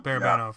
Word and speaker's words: Barabanov: I'm really wondering about, I don't know how to say it, Barabanov? – Barabanov.